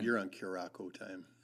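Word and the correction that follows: Curaçao: But I always thought it was Blue Curaco when I was a kid. Should be Blue Curacao.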